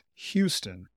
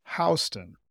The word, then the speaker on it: Houston: The city in Texas is pronounced Houston, while the street in New York is pronounced Houston.